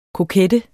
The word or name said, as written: kokette